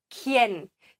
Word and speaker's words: quién: Q-U ist ähnlich wie ein K. ¿Quién? Sag nicht ¿quién? Ja, das U wird hier nicht gesprochen.